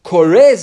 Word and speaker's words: kore: Kore zachar. Is it pronounced kore? – Yeah.